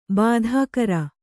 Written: ಬಾಧಾಕರ

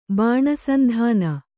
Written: ಬಾಣಸಂಧಾನ

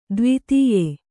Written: ದೇವಾದಾಯ

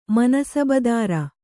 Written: ಮನಸಬದಾರ

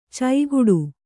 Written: ಚೈಗುಡು

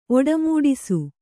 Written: ಒಡಮೂಡಿಸು

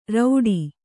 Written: ರೌಡಿ

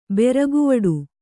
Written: ಬೆರಗುವಡು

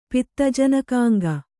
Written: ಪಿತ್ತಜನಕಾಂಗ

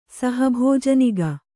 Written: ಸಹಭೋಜನಿಗ

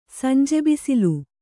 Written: ಸಂಜೆಬಿಸಿಲು

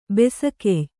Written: ಬಿಸುಗಯ್ಯ

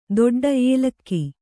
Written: ಏಲಕ್ಕಿ

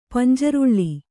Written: ಪಂಜ್ರೊಳ್ಳಿ

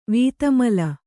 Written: ವಿಧುಮೌಲಿ

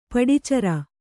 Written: ಪಡಿಚರ

